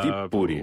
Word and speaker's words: Виипури: У них всегда Виипури, ударение, да, на первый слог.